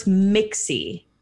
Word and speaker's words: Mixie: I feel like I don't pronounce my name clearly. – Okay. – So it's Mixie, everyone.